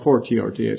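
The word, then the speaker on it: a fortiori: That's a fiori. Or fortiori, excuse me.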